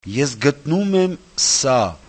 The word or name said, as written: esgtnumemsa